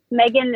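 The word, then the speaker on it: Maegan: My Instagram name is Megan Molnar. Megan is spelled a little funky. It's M-A-E-G-A-N Molnar, M-O-L-N-A-R.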